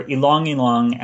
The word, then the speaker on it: Ylang Ylang: So Ylang Ylang, or Ylang Ylang, as I'm told the natives pronounce it, it's really interesting.